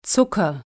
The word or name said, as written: Zucker